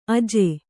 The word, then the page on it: ಅಜೆ: aje